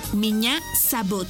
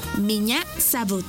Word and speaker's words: Меня зовут: Y se escribe P-R-I-V-I-E-T. Привет. ¡Muy bien! Repite conmigo. Привет. ¡Muy bien! En ruso, me llamo se dice Меня зовут. ¿Y tu nombre? Una vez más. Меня зовут. Por ejemplo, Меня зовут. Esta palabra se escribe Меня. M-I-Ñ-A. Меня зовут. Z-A-V.